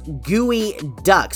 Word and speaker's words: geoduck: Enter the geoduck, pronounced gooey duck.